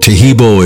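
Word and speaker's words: Taheebo: Tehibo is spelled T-A-H-E-E-B, like boy, O, then the word T, and then the word club.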